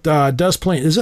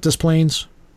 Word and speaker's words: Des Plaines: The first brewery to open in Des Plaines, am I pronouncing it right, Des Plaines? Or is it Des Plaines? – Okay, I'll say it as Des Plaines.